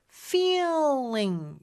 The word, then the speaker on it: feeling: 그리고 feeling, 요거 E 발음 길게 끌어줘야 되는데요. E 하면서 L 발음 날 때 약간 꺾이는 느낌이 있어요. feeling, 요렇게.